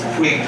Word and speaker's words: Puig: Puig Destroyer. – Puig Destroyer.